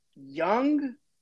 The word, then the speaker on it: Jung: You know, Josh Young, but it's spelled Jung.